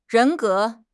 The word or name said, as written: 人格